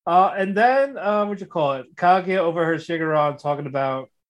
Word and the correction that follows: Shirogane: Why do you say Shirogane? – Yeah, it's his name. Shirogane. – Shirogane. You gotta say it properly, goddammit.